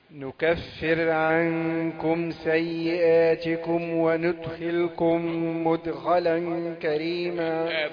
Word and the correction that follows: وندخلكم: ضمت النون اللي جت ضعيف. النون وندخلكم. حرك الفم، خلي ادوات النطق تكون سلسه. وندخلكم، وندخلكم مدخلا كريما.